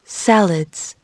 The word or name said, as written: salads